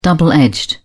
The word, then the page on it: double edged: British English